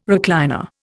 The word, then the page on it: recliner: Indian